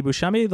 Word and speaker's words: Buscemi: And my name is Andy Buscemi.